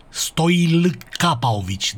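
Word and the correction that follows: Stojilković: deci așa se pronunță, Stoilkovici, cu L la mijloc,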